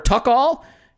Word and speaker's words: Tuchel: So Andrew Brook at Andy Brook, A-N-D-E-B-R-O-O-K, and Brook, and Ebrook says, how do you pronounce T-U-C-H-E-L? Is it Tuchel or Tuchel? We actually have a response to that saying it's Tuchel.